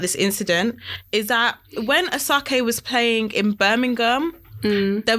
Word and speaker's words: Asake: Asake.